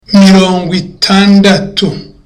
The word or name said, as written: mirongwitandatu